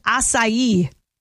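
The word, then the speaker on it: açaí: The number one most mispronounced word, though, is acai. Yeah, it looks like a K-A-C-A-I, like an acai bowl.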